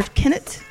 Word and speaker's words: Kennet: Is that name River Kennet? – Kennet. – K-E-N-N-E-T. – Sounds about right.